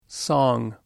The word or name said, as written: song